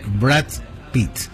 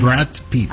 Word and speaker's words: Brad Pitt: Me dijeron, se dice Brad Pitt. – Muy bien. – Brad Pitt.